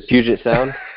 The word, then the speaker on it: Puget Sound: If you ask him to swim the what's that big the putrid the putrid sound, I probably mispronounced the, that. The Puget Sound.